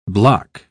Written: bloc

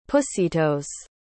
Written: pussytoes

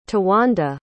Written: towanda

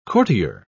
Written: courtier

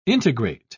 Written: integrate

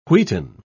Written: wheaten

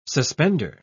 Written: suspender

